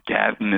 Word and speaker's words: Gadden: There is another permanent exhibit, the Gullah Geechee Gadden exhibit, and Gadden is the way that garden would be pronounced in some Gullah Geechee communities,